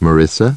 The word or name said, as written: Marissa